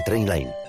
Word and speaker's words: Trainline: Se pronuncia Trainline.